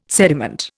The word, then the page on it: sediment: Indian